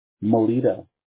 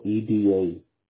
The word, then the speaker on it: Molleda: Last name, well, it's a Hispanic last name. It's actually pronounced Molleda, but in English, mm-hmm. – when I was growing up through high school, all my teachers would say Molleda, so it stuck.